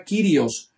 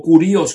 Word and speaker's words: Kyrios: Cuando nosotros estudiamos el Nuevo Testamento, la palabra dominante que se traduce al español como Señor es la palabra Kyrios o Kurios, como dicen otros. La pronunciación puede variar, pero se escribe igual.